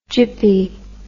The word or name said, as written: gyppy